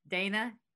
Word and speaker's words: Dana: because it's D-A-N-A. And it, I guess Dana over here is Donna over there. – Okay. So just a note to our fans across the pond, I totally get that you guys pronounce it Donna.